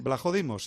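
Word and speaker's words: Vlachodimos: Blajodimos, sí, sí, así se pronuncia, Blajodimos.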